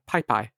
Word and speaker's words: PyPI: It's PYPI. PyPy. – Ah, yes. – And it's not pronounced any other way.